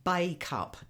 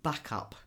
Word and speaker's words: Bacup: Now, it's pronounced Bacup, not Backup, which is how I've always wanted to say it as a southerner.